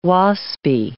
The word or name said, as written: Waspy